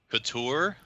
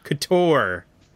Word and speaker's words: couture: How do you say that? – Couture? – Couture. – Sure. – Is that French? That's a French word.